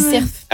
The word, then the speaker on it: cerf: Mais du coup, arrête-moi si je dis cerf. – Ah oui, cerf. Ouais. Ah, dans le sud-ouest, on prononce toutes les lettres. – Et dis le F.